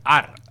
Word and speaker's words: Are: ¿Vos qué lo he dicho bien? – No, Are no. – No. – Are. – Are. Are. Vale. Are Back in Town.